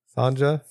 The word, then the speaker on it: Sonja: Sonja. – Oh, is that the Sonia. Y is silent? – The J is silent.